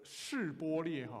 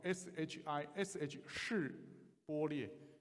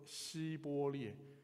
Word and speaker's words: 示波列: 考你的发音，卷舌音以法莲人发不出来哈。所以呢，有一个字叫做这个示波列哈，SHISH 示波列。以法莲人没有办法卷舌，他就说希波列啊。